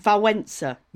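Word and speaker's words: Wałęsa: But his name, I've looked it up on the BBC pronunciation, apparently it's Walesa.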